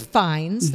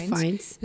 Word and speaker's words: Fiennes: Ralph Fiennes, that's how that's pronounced. – Yep, Ralph Fiennes.